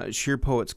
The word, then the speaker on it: Sheer Poets: Where did the whole idea for Sheer Poets, and I hope I'm pronouncing that correctly, Sheer Poets come from? – You nailed the pronunciation.